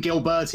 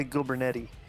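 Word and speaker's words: Gilbertti: Did I say Glyn Gilberti? Did I say that right? – Yeah, that's right. Vince still calls me Gilbernetti.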